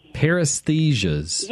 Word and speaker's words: paresthesia: It's P-A-R-E-S-T-H-E-S-I-A. – Paresthesias.